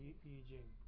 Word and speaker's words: Yi Jing: Yeah, that's the technical right, correct pronunciation, Yi Jing.